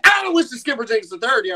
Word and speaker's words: Aloysius Skipper Jenkins III: The correct pronunciation is Aloysius Skipper Jenkins III.